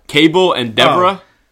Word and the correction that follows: Cabal: Cabal. – You were 2 for 2 in warm-ups. – Cabal and Deborah.